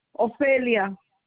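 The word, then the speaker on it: Ofelia: Ophelia. – Can you spell it for me? – O-S-E-L-I-A. – O-S-E-L-I-A? – No, O-S as a friend, E-L-I-A, Ophelia.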